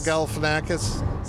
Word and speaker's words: Galifianakis: How do you pronounce this? – Zach Galifianakis. – Galifianakis.